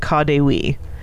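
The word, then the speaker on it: KaDeWe: But luckily it's commonly called Ka de Wee. So, because it's Kaufhaus de Westen, so Ka de Wee.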